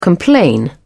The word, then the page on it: complain: British English